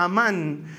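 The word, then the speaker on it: amán (אמן): Literalmente emuná viene de una palabra hebrea también que se pronuncia amán y que literalmente significa algo firme o estable en lo que es seguro confiar.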